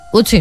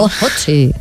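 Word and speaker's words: Ötzi: Otzi, creo que se llamaba, un nombre muy, muy noruego. – Utzi, ¿no? – No sé, lo pronuncias tú mejor que yo. – Utzi, vale.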